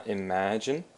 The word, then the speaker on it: imagine: So to imagine, of course, the emphasis on the ma, imagine.